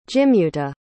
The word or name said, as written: jimuta